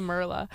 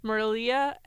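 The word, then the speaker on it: Merliah: I don't know why I keep wanting to say Merla. Merlia and her two friends are like big into surfing and they have the cute little bathing suits on.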